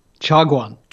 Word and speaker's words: Chaguan: I butchered that word, didn't I? – That was pretty good. – How do you say it? – Chaguan. – Chaguan. Chaguan. Yeah. All right. See, I've already learned something in the new year. Chaguan. – It means tea house.